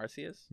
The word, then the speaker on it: Arceus: Arceus? – Mm-hmm. – Arceus? – Yep.